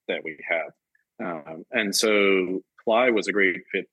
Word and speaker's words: Plai: Is it pronounced Play? – Ply, actually. I think it has some meaning in Ukrainian, I believe. – Spelled P-L-A-I.